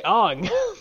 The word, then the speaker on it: Aang: I just said Aang. No, that's a fucking terrible pronunciation in the fucking the movie adaptation. It's Aang. God damn it! Why did I say Aang? – No idea. – I don't know. Maybe I'm second guessing myself on all the name pronunciations because of the I mean, that's possible. It is certainly spelled in a way that would make an English speaker assume "ong" because the double A should be long, but.